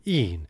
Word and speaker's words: e'en: And note that cheered is pronounced here with two syllables, cheered, and even with one syllable, een, in order for the line to scan.